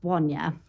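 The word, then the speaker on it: Bonia: Uh, spelt Blonny Brewery, it's, but it's pronounced Bonya. of course.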